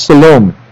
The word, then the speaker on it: Salome: I believe her name is pronounced, it's not Salome, it's Salome. S-A-L-O-M-E is her name.